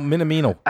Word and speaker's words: Minamino: I mean, the Minamoto, is that you say his name? – Minamoto? Yeah, yeah Minamoto. – I'm going to go with that. No, Minamino. Minamino. – I oh, keep saying Minamoto.